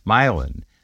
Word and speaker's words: Milan: And Milan is spelled like Milan, Italy, but it's called Milan.